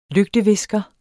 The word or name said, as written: lygtevisker